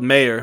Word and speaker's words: Mayer: It's spelled Mayer, but you pronounce it Mayer.